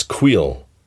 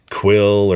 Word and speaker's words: Queel: Now we have the correct pronunciation, Quill or Queel. And it's like, no, it's Queel. It's the end of squeal.